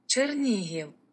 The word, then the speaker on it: Chernihiv: Chernihiv.